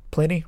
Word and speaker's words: Plini: Pliny? – Pliny. – Yeah. – I never know how to say their name. – P-L-I-N-I, right? – Yeah, P-L-I-N-I.